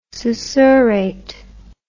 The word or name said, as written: susurrate